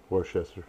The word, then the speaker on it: Worcester: How do you say that town? Worcester? – Worcester.